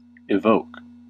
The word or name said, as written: evoke